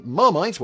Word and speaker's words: Marmite: The name Marmite, while pronounced in English as Marmite, was derived from the French marmite,